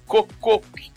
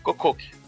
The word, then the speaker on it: Kokkoku: Esse é bem legal mesmo, que é o chamado Kokok. Se escreve K-O-K-K-O-K-U. Kokok.